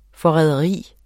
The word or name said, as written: forræderi